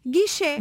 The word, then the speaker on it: guiché: Porque a língua é assim, vá-se lá saber, a língua é assim, houve esta evolução fonética, e portanto a pronúncia correta em português é com E aberto, guiché.